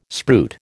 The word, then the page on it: spruit: American English